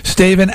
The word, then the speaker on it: East Haven: Staven actually refers to East Haven. Says East Haven has a large Italian population, and Staven refers to the way many of the townspeople pronounce the town's name.